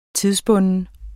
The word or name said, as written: tidsbunden